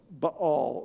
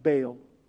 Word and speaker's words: Baal: known as Baal, or sometimes pronounced Baal.